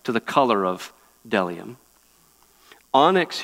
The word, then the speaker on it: bdellium: And some of you are saying there's a B there, but you don't pronounce the B. Bdellium, no, it's bdellium and onyx stones, which are there.